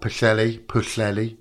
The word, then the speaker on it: Pwllheli: It's in a place called Pwllheli. That's how you say it. Pwll meaning pond and heli meaning salt in Welsh.